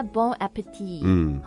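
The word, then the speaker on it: bon appétit: 是吧、应该 Bon appetit 后面那个 t 不发音的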